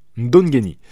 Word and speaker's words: Ndongeni: D'abord, Arnold Barboza Jr. qui a battu pardonnez-moi pour la prononciation, Ndongemi. Xolisani Ndongeni.